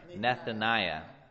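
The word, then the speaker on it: Nethaniah: Jehudai, the son of Nathaniel, or no, sorry, Nathaniah.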